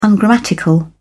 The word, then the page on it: ungrammatical: British English